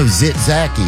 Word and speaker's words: tzatziki: Like the Greek? Zach sauce? – No, tzatziki. Bring it up. – No. – Tzatziki. – I've never heard of that. – Tzatziki?